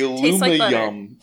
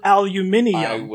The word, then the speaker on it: aluminium: The aluminum tastes, it has a taste. – Yeah, like butter. – Okay, yes. – The Britons will pronounce it aluminium.